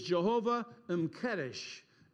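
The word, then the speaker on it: Jehovah M'Kaddesh: And this combination of Lord, capital L-O-R-D, who sanctifies, is Jehovah M'Kedesh. Jehovah M apostrophe K-A-D-D-E-S-H is how we would spell it in English.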